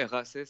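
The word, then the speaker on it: Raasay: Ouais, Raisé, ça s'écrit RAISAY.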